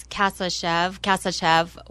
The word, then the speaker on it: Kasachev: And it's Kasachev with the last part of it, Chev, like the Chevy car.